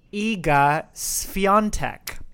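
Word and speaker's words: Świątek: However, we have recently been educated, and we now know how to pronounce the women's draw's new French Open champion. – And it's not Zachary. Whose name we were also mispronouncing for Evs. – That is correct. Zachary did not win. It is our new Polish phenom, Iga Swiatek.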